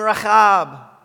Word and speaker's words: Rahab: Yes, you pronounce her name Rahab. Try it. Rahab. It's good in the winter to do that. It helps your throat.